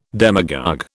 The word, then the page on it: demagogue: British English